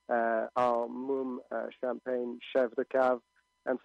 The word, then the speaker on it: Mumm: Moom, it's spelled M-U-M-M for those who don't drink champagne, and you may want to try it because it is fantastic.